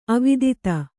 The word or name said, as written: ಅವಧೂತ